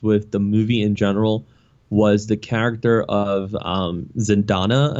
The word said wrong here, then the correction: Zendaya: I think her name is, if I'm saying it right, even uh, Zendaya. – Yeah, um, her, uh, yeah, I think it's Zendaya.